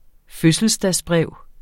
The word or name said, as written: fødselsdagsbrev